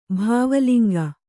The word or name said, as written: ಭಾವಲಿಂಗ